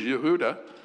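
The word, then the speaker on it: Yehuda (יהודה): And it is pronounced in their language, Yehuda. Then they changed it into Judah.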